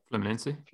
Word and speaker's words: Fluminense: And then I had my Forte Laser defensive stack playing against Fluminense, I think. Is that how you pronounce it? – Fluminense, yeah.